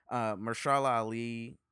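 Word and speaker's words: Mahershala Ali: Mahershal Ali, am I saying his name correctly? – Yeah, for Moonlight.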